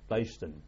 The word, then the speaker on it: Leiston: From Fairford, we went back to the East Coast at Leyston. – Leyston? – Leyston, that's Suffolk. – No, you spell it. – L-E-I-S-T-O-N.